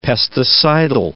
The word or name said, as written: pesticidal